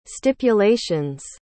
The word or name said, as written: stipulation's